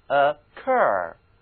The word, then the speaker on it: occur: 注意这个词它的重音呢、在后半个音节上， occur 读音一定要准确。